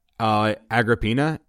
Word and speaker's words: Agrippina: I'm saying Agrippina because Agrippa is A-G-R-I-P-I-A. Agrippa. – What is it? – Agrippina, A-G-R-I-P-P-I-N-A.